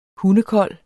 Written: hundekold

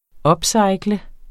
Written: upcycle

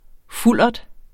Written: fuldert

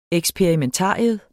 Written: eksperimentarium

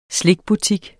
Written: slikbutik

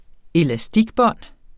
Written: elastikbånd

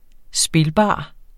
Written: spilbar

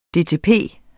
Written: dtp

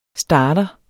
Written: starter

